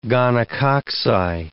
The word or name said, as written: gonococci